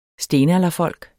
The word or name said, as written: STENALDERFOLK